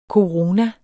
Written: korona

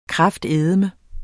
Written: kraftedeme

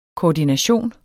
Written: koordination

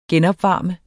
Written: genopvarme

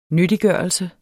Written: nyttiggørelse

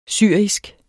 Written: syrisk

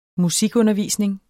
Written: musikundervisning

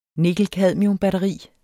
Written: nikkel-cadmium-batteri

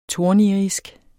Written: tornirisk

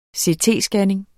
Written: CT-scanning

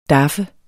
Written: daffe

